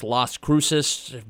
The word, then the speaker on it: Las Cruces: and I believe it's Las Cruces.